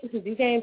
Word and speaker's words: Duquesne: Okay, so type up 13, E-R-W-I-N, Duquesne, PA. That is D-U-Q-U-E-S-N-E, Duquesne. That's not spell how it sounds, but I don't know.